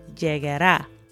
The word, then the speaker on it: llegará: So for example, it starts out, pronto llegará. So that llegar is followed by the a sound at the end, and that's where the emphasis is.